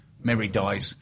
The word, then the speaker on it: Meridies: Meridis. Meridies.